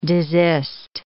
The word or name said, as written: desist